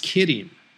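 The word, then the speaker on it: kirin (麒麟): By the way, in Japanese, the word qilin is pronounced kirin, just like the bear.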